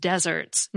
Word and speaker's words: desert: The arid place, desert, on the other hand, has one S and the first syllable is stressed. The noun that means what you deserve, spelled D-E-S-E-R-T, with one S, confusingly has the second syllable stressed, just like the word that refers to cake or cookies.